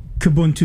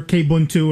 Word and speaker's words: Kubuntu: Well, it depends on which version of Kubuntu you have. For those of you who don't know, Kubuntu or K-Ubuntu or K-Buntu or whatever you want to pronounce it, is Ubuntu a version of Linux using the KDE front end, the graphical user interface, the GUI.